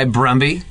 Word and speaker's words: Brumby: There was a director, this guy Brumby, whose name rhymes with Gumby, so I like him already.